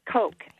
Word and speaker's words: Koch: And I had one that continually told me, you have to read The Dinner by Herman Koch, I believe is the spelling of his last name or the pronunciation. – K-O-C-H, is yeah, that it? – It's right.